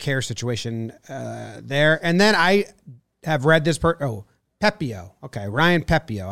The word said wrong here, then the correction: Pepiot: I've always read it, but I'm not positive. We have a pronunciation guide here. – Pepiot. – Pepiot. Ryan Pepiot.